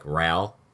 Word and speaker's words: Rahl: So, one of the characters has, like, the last name Rahl, which is R-A-H-L. Mm-hmm. And it wasn't this name, but it was as if I read six books in the main character's last name as, like, Rahl. And then I realized there was an H in there. And I was like, oh, shit. – Yes. – It's pronounced Rahl.